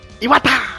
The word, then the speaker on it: Iwata: Senhor Iwata!